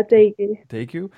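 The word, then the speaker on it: degu: Am I saying that correctly? – Yeah, Daegu.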